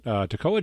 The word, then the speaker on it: Toccoa: I looked up the diacritic, that's how you pronounce it. Toccoa, Georgia, the Boxman, ending the set with You Tell Me.